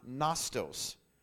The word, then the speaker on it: gnostos: One of the words for knowledge is the Greek word gnostos. And if you're taking notes, it's spelled G-N-O-S-T-O-S.